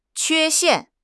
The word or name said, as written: 缺陷